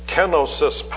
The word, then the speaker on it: kenosis: But it's Philippians chapter 2 and verse 7, the great kenosis passage in which, I pronounce it that way because of the accent in the original text,